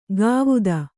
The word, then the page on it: ಗಾವುದ: gāvuda